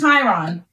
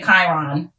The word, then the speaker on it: Chiron: But it's Chiron. I've been listening to the audiobooks and they say Chiron.